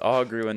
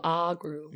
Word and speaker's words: Ogru: I believe it's Ogru and not a long O. – Well, Instead of ogru, agru.